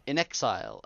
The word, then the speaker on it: inXile: So, Wasteland Studios, Inxile Entertainment, did I say that right? Has two new RPGs in the works? – In Exile.